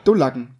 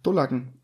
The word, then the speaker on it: Dullacken: Also die Betonung ist auf jeden Fall aus dem U, auf dem U. Dullacken, Dullacken.